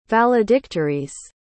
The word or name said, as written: valedictories